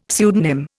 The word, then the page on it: pseudonym: Indian